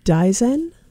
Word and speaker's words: Daizen: So you can reach me at Dizen at